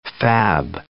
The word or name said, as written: fab